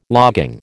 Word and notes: logging: American English

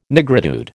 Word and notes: nigritude: American English

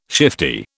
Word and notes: shifty: British English

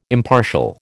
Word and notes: impartial: American English

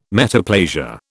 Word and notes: metaplasia: British English

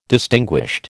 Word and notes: distinguished: American English

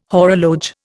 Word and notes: horologe: Indian

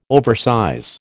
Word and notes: oversize: American English

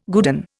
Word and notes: guerdon: Indian

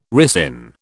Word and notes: ricin: British English

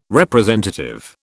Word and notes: representative: British English

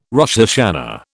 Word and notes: Rosh Hashana: British English

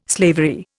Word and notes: slavery: Indian

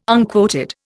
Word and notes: unquoted: Indian

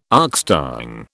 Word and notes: oxtongue: British English

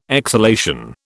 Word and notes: exhalation: British English

